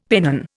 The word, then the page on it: pennon: Indian